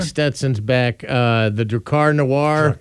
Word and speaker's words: Drakkar Noir: Drakkar Noir. – Drakkar Noir.